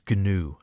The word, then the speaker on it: gnu: As it says, always pronounced these days like a two-syllable word, gnu. I think it used to be gnu originally, doesn't it? Gnu, I don't know how you pronounce it originally. I've always pronounced it gnu, it has to be said.